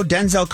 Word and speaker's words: Denzel: He said it sounds more like Denzel, D-I-N-Z-I-L. And then he clarified saying that his dad did the same thing as well. So Denzel, not Denzel.